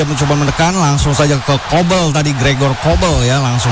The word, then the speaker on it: Kobel: Kobel ya, Kobel ya, spelling ya. – E-nya agak beda nih.